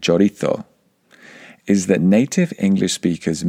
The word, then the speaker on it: chorizo: If we try to pronounce these French words like the French, then we should try to pronounce the Spanish words that are in the English language like the Spanish. So we should say paella. We should say chorizo.